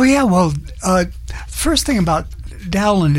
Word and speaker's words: Dowland: But most consensus now say that it's a short O, because there's a composer by the name of Cowper who's contemporary, and so Cowper, Dowland, something like that.